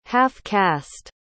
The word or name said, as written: half-caste